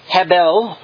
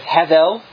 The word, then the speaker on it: hevel: comes from the Hebrew word hebel or hevel. It can be pronounced either way. I'm not sure what drives that. I'm going with hevel as the pronunciation of this Hebrew word.